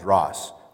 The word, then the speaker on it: dross: That junk is called dross. Rhymes with cross.